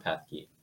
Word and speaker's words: Pethke: Yeah, I'm John Pethke.